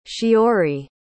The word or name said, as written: shiori